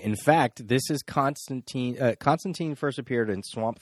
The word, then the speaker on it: Constantine: Alan Moore went out of his way to tell us that it's Constantine rhymes with sign.